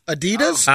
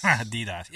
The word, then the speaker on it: Adidas: What? Adidas? – Oh. Adidas. – Adidas. Yeah, say it right.